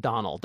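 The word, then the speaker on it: Domhnall: It's spelled, let me see if I, it's an Irish name. D-O-M-H-N-A-L-L, I think. Yeah, but it's pronounced Donald.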